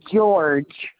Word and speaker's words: George: Last name is George, GEORGE.